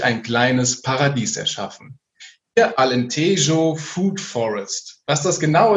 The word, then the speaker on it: Alentejo: genauer gesagt in die Region Alentejo, habe ich eben noch gelernt, wie ich das richtig ausspreche, ausgewandert.